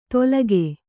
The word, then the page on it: ತೊಲಗೆ: tolage